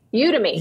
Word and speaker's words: Udemy: Is that, as I say it right? Udemy. – Udemy. – Yep. Thank you.